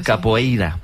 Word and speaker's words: capoeira: Pues todos sabemos lo que es la copeira, ¿verdad? Capoeira. Qué mal pronuncio últimamente.